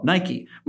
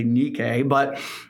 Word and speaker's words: Nike: When it's so good that as soon as you put the logo up, everybody goes, "Oh, Nike." Well, that's a little bit of a mispronunciation. It's actually Nike, but still, this is the victory that overcomes the world.